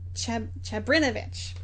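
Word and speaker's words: Čabrinović: Just a couple days before the visit to Sarajevo, that one of the conspirators, Nedjeljko Chabrinovic, that is how it's pronounced,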